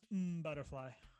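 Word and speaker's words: M. Butterfly: And I, it's it, it's pronounced mm, Butterfly.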